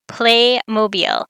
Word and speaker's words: Playmobil: So I looked into a little bit, and it is Playmobil, as in the city in Alabama. So like Mobile, Alabama, that's how you pronounce it.